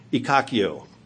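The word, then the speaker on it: ekkakeo: The Greek word translated lose heart or to faint or to give up in the English is spelled E-K-K-A-K-E-O. E-K-K-A-K-K-E-O, Ikakio, which is Strong's Concordance word number 1573, if you want to look it up.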